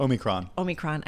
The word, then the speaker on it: Omicron: Did the Omicron variant, did I say it right? – Omicron. – I knew I said it incorrectly.